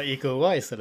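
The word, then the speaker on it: Iko Uwais: Ja, men det är ändå Statham, det är Iko Ovai, Ovai, hur fan man uttalar det. – Vänta, va? – ja men The Raid-skådisen, hur fan man uttalar namnet. – Ja, eco Wise eller? – Precis. – det var ju det jag sa.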